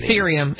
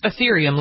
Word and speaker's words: Ethereum: It should be pronounced with an E. It is. – It's Ethereum. It's Ethereum, like ethereal.